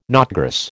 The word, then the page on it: knotgrass: American English